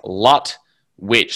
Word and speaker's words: Lutwyche: For the record, it's Lutwyche, so it's L-U-T dash W-I-T-C-H.